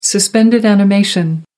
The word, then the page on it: suspended animation: American English